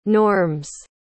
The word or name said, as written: norms